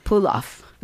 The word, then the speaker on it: Ploghoft: How do you say it? – Puloff. – Puloff. Okay. It's spelled P-L-O-G-H-O-F-T.